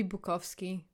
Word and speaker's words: Bukowski: but like who also doesn't know how to pronounce his own name, Bukowski. – It should be Bukowski, but like who cares?